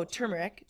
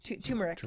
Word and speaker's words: turmeric: Oh, turmeric. Turmeric. Is that how you say it? Yeah.